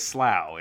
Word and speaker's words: Slough: You're not just pronouncing slow as slough, it's actually slough.